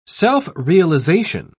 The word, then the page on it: self-realization: British English